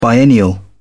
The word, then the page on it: biennial: British English